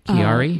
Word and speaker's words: Chiari: Did you begin to discover that the, how do you say it, Chiari? – Yes, C-H-I-A-R-I.